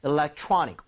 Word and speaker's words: electronic: So electronic 이렇게 하지 마시고 electronic 이렇게 억양을 살리면서 그러니까 강세를 주면서 한번 두 번 발음해 드릴게요.